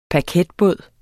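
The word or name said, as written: paketbåd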